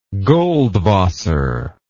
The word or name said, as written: goldwasser